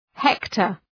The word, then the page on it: hector: ‘hektər